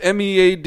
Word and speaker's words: Meade: It's Meade. – Yeah. – Is it Meade or Meade? – No, it's Fort Meade. – Meade. – That's how I've always heard it pronounced.